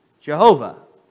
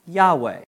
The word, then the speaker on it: Yahweh: So some people believe that it should properly be pronounced Jehovah. Other, peop- uh, other people believe that it should be more accurately Yahweh. So you're, you're referring to the very same name. Yahweh is probably closer because there was no J sound, it would have been a Y sound.